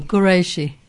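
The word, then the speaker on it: Quraishi: Qureshi. – Qureshi, Q-U-A-R-A-I-S-H. – I. Q-U-R-A-I-S-H-I.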